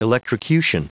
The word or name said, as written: electrocution